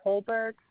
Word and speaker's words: Colberg: Carlene is spelled K-A-R-L-E-N-E. Last name is Kohlberg, C-O-L-B-E-R-G.